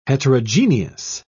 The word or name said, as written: heterogeneous